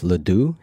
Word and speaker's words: LeDoux: One of the other things that you wrote about recently, which I thought was interesting, was the scientist Joseph Ledoux.